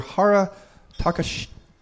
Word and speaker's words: Hara Takashi: Japanese Prime Minister Hara Takashi? – Yep, exactly. – Yep, that's how you say it? – Mm-hmm.